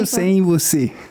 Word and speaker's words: Eu sem você: Eu sem você. – Ja, genau, so spricht man das richtig aus.